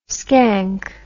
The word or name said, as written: scank